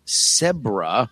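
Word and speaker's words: CEBRA: They then trained a machine model called Zebra, that is pronounced zebra, but it looks like Zebra because it starts with a C, to link the neural data to that video clip.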